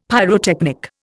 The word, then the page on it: pyrotechnic: Indian